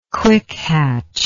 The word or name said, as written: quickhatch